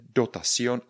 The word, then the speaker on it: dotación: A dotacion, tak jak slyšíte, dotacion a pozor, to sion, jak bude napsaný? je tam napsaný dotacion, protože víme, že C a I je eh, vyslovujeme jako S, tedy dotacion.